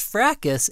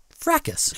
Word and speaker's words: fracas: And did you know that in the British English, the S is silent? In England, they say fraca, but in America, we pronounce it fracas.